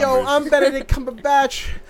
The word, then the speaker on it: Cumberbatch: I thought his name was Cumberpatch. – That's what I thought, too. – Like a cucumber patch. – Benedict Cucumber Patch. – Wait, have you ever seen the videos of him pronouncing penguin? – No. – So funny. – How does he say it? – He says it as Ponguin? – Ponguin. Oh, what? His name's Cumberbatch?